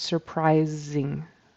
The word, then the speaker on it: surprising: So the only difference was, I think he had an S instead of a Z sound for that S in surprising, the last syllable. So it's surprising.